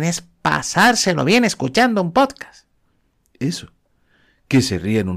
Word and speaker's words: podcast: La Real Academia de la Lengua dice que podcast, con acento en la pop, que es donde lleva la mayor fuerza de voz las sílabas de las palabras en inglés.